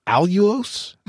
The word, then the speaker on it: Allulose: Allulose? Am I saying it right? – Yes, it's a very innovative sugar, and it's actually a natural sugar.